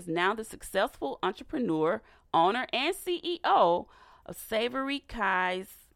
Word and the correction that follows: Savory Keys: Did I say that right? Savory Keys. Savory Keys. I'm sorry.